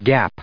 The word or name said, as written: gap